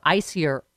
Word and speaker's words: Aisier: My new favorite, Icier. Oh my God. It's spelled A-I-S-I-E-R, Icier.